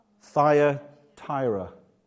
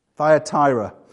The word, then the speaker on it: Thyatira: I always say this wrong. It's Thyatira. Thyatira, the one with eyes of fire and feet of burning bronze, to Sardis, the one who holds the stars, the creator.